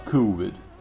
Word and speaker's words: COVID: Вот Вэл говорила, я на всякий случай напомню тем, кто прослушал подкаст про «Будьте здоровы», что по-английски это будет uh, а, COVID с дифтонгом и ударением на первый слог.